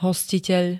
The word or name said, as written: hostiteľ